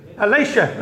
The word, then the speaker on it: Elisha: You have Elijah, how do you say this? Elisha, that's it, brother.